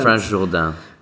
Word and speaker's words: Jourdain: The Salon d'Automne, or the Autumn Salon, it was founded in 1903 it was the brainchild of Franz Jourdain. Have I pronounced that correctly? – Yeah. – Excellent. Your confidence there fills me with confidence. – Franz Jourdain.